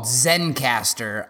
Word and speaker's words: Zencastr: So we use a tool called Zencastr. Z-E-N-C-A-S-T-R. So no E if you were spelling Zencastr phonetically.